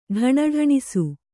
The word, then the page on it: ಢಣಢಣಿಸು: ḍhaṇaḍhaṇisu